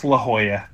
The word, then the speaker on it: La Jolla: Anyway, it's La Jolla, but I like to say La Jolla because that gets me into parties talking like that.